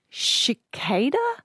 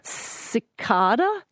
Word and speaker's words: cicada: The word Kel was saying there is C-I-C-A-D-A. – Yes. – The insect. – Which we got from Latin, and it's cicada in Latin and cicada, which is an alternative pronunciation. Both of them are found, both of them are listed in the dictionary, but definitely no sh at the start.